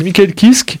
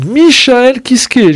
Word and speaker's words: Michael Kiske: Alors, là, c'est la vraie prononciation. Hein. Il y en a qui disent Michael Kiske. Non, c'est Michael Kiske.